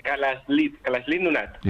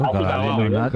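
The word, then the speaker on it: Kalaallit Nunaat: Nada, allí cuando juntan las dos L's le ponen una S y una L. Kalalit Nunaat. – Igual que lo he dicho yo. Kalalit Nunaat. – Kalalit Nunaat.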